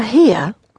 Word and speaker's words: hier: The word for here is here. Here, spelled H-I-E-R. Here. So how would you say it is here? – It is here.